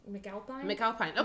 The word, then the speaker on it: McAlpine: McAlpine.